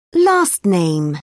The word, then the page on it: last name: British English